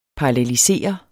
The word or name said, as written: PARALLELISER